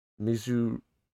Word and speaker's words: Mizutsune: Next one up, Monster Hunter Rise Sunbreak adds 3 new monsters, including Violet, Mizutsune. How do you say that, Sean? – You said it right.